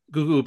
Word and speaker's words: Gugu: I don't think it's Gugu, but it's G-U-G-U. Mbatha Raw is her name. – Yeah, it's Gugu Mbatha. – Okay, thank you. Thank you. Gugu Mbatha.